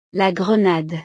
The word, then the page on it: la grenade: French